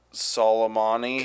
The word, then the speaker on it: Soleimani: Soleimani.